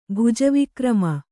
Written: ಭುಜವಿಕ್ರಮ

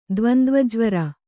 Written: ದ್ವಂದ್ವಜ್ವರ

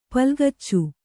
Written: ಪುಲ್ಗಿಚ್ಚು

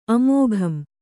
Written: ಅಮೋಘಂ

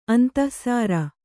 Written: ಅಂತಃಸಾರ

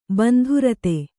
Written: ಬಂಧುರತೆ

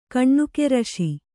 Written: ಕನಕರಿಸು

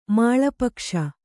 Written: ಮಾಳಪಕ್ಷ

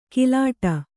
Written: ಕಿಲಾಟ

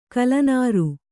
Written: ಕಲ್ಲನಾರು

